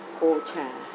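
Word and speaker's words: ojā: Talking about the Kalapa, and then one of the 8 inseparable rupa is the nutritive essence, the Oja. And then here the book says... – Actually, it's pronounced Ocha.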